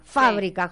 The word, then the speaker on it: Fábrica: Tiene el acento en la A. Fábrica, sí.